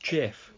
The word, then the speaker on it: GIF: Although it's actually pronounced GIF, if you believe the person who invented them.